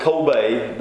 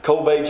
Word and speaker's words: Kobe: They pronounce it Kobe there. Kobe, Japan.